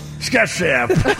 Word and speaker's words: Scott Stapp: Scott Stapp.